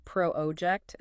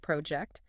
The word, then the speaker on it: project: If you put the emphasis on the wrong syllable, saying pro-object instead of project, a native speaker might genuinely have no idea what you're saying, even if your grammar is flawless.